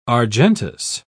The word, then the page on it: argentous: British English